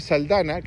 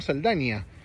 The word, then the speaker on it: Saldana: Muy buena, de Zoe Saldana, que hace poco me enteré que era Saldana y no Saldania. Yo siempre le dije Zoe Saldania, pero Zoe Saldana, trabaja Nicole Kidman, vi en el póster, pero no apareció todavía por donde voy yo, que está Morgan Freeman.